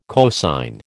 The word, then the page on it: cosine: American English